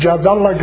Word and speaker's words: Jadallah: My name is Jadallah Graib. The time I came to America, I clipped Allah from my name, which is Allah meaning God. For you, American, you can say my name, Jad.